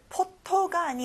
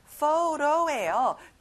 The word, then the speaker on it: photo: 친구들, 포토가 아니라 photo예요. T 발음은 안 나요. 그리고 O 소리를 두 번 내야 돼요.